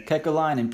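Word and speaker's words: Kekäläinen: But talk about the trade deadline acquisitions a little bit, because he's pretty – Jarmo Kekalainen, is that how you say his name? – Kekalainen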